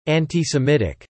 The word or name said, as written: antisemitic